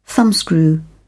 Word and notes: thumbscrew: British English

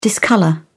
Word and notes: discolour: British English

